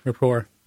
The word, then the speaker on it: Rapport: Is it pronounced Rapport? Is it like Colbert Rapport, where it's Rapport? – Yeah, Rapport.